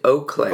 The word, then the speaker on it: Eau Claire: I believe Eau Claire. Eau Claire is how it's pronounced. Eau Claire, I believe.